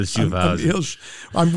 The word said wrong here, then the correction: Schaffhausen: We are butchering his name. – His German accent. – Say his name one more time. – Emil Schaffhausen. – Read it. I want you to read it to confirm it. – Schaffhausen. – Schaffhausen. – Yeah.